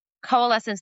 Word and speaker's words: coalescence: You develop what you call the consolence method. Now, am I saying that right? Consolence? – Coalescence. – Like it's marrying, I was close.